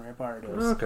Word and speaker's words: Rampardos: I was saying it right the first time, Rampardos.